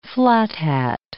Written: flat-hat

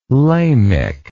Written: lamech

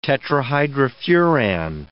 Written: tetrahydrofuran

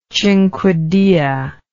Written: cinquedea

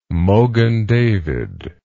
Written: Mogen David

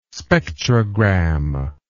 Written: spectrogram

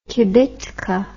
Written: kibitka

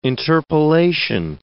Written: interpolation

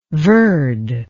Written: verde

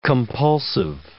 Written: compulsive

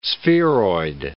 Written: spheroid

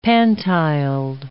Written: pantiled

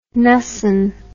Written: Knussen

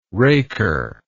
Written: raker

